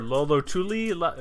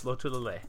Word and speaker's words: Lotulalei: I hear Lolotuli. No, it's Lotulalei.